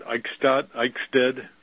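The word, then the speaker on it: Eichstaedt: Eichstadt.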